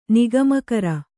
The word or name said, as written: ನಿಗಮಕರ